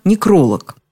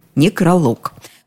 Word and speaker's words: некролог: Обратите внимание на то, как произнести это слово «некролог». Вроде и не самый подходящий момент обращать внимание на ударение, но что делать, если именно в этом слове ошибку совершают очень часто? Говорят обычно «некролог». Между тем, такое ударение, неправильное с точки зрения русского языка, действительно существует, но в сербско-хорватском языке, а во всех остальных славянских языках – некролог.